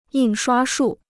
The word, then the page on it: 印刷术: yìn shuā shù